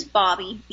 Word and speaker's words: Bobbi: Okay, well, my first name is Bobby, B-O-B-B-I, and then Jean, J-E-E-N. – Okay. And so last name? – is Olson, O-L-S-O-N.